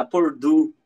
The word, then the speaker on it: Purdue: Então a Purdle, eles a Purdue, né, como o William trouxe aqui a pronúncia e acredito que seja correta.